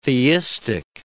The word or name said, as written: theistic